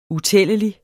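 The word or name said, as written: UTÆLLELIGT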